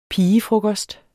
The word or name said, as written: pigefrokost